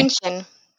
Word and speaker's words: München: München. Yes. München.